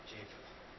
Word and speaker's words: Japheth: The sons of Japheth, pronounce that for me. – Japheth. – Okay.